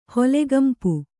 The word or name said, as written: ಹೊಲೆಗಂಪು